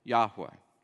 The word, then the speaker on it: Yahweh: Yahweh.